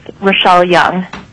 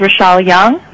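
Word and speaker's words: Rachelle Yeung: My name is Rochelle Young. For the record, that is spelled R-A-C-H-E-L-L-E. Last name Young, Y-E-U-N-G.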